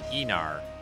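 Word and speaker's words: Aenar: There's this week's look at the Anir. This episode, did I say that wrong again? Enar. Gosh, I keep doing that. Excuse me. Sorry about that. Enar. The Enar.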